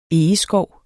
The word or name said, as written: egeskov